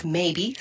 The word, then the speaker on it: Maybe: Maybe se escribe M-E-I-B-I.